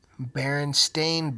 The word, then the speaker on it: Berenstain: That's right, Berenstain Bears. But why is it called the Berenstain Bears if it's spelled Berenstain? B-E-R-E-N-S-T-A-I-N. Berenstain Bears is what it's called now.